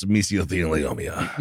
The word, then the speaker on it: mesothelioma: Or it's mesothelioma. – Perfect. – Mesothelioma. – Wow, you know how to say it after all your doctor's visits.